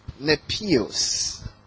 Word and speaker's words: Nepios: Nepios. Amen. Nepios.